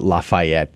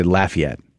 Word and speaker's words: Lafayette: By the way, they don't pronounce it that way. It's not Lafayette. They say Lafayette, just so you know.